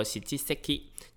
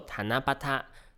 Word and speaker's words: 七夕: 不过在日文当中呢，七夕的念法通常不会直接念作喜七 seki，就是不会直接念七夕这样子，而是会念作塔那巴塔。